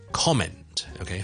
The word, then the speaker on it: comment: 俾多个例子，譬如我哋读 comment，OK，、 okay? 去评论一啲东西，我哋 comment，亦都系 com 嘅，我哋唔系读 comment 嘅，OK。